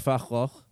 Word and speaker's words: Van Gogh: Did you know that Van Gogh is apparently pronounced Fachloch? – Terrible. – Yeah, didn't know. – A terrible real pronunciation. Yeah. Van Gogh's so much better.